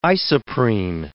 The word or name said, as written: isoprene